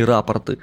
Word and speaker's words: рапорты: Судья вызывать их отказался, сказал, что в деле имеются подробные рапорта и объяснения. Именно поэтому я делаю такое ударение в этом слове, а не рапорты, как правильно обязательно надо говорить.